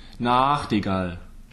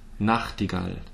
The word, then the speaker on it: Nachtigall: yes, and this is because I've often heard Nachtigall. – Ooh. – Ooh, right. That's what I thought, but I never said. so um, it's Nacht, Nachtigall, Nachtigall. So it's that short syllable.